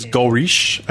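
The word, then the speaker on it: Godrèche: I think it's Goldrich. I would believe the D would be silent, since it's a French name. – The D is silent.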